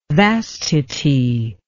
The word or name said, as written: vastity